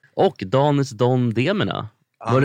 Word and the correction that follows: Anis Don Demina: Anis Don Demina. Det är så dåligt. Donis... Nej, Anis Don Demina.